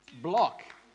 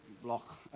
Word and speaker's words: Bloch: Did I say that right? Block. There's no K there, it's a H. Block.